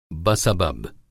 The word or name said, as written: बसबब